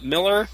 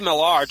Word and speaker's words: Millar: Have you read the Miller? I believe it's pronounced Miller, although it's... – Yeah, it is Miller. It's spelled Millar, but it's pronounced I'm Miller. Tempted to say Millar just so people know who I'm talking about, as opposed to, like, I don't know, like Frank Miller or whatever.